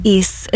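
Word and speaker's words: Ys: East. It's spelled Y-S. – Oh, it's pronounced East.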